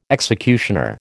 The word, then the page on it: executioner: American English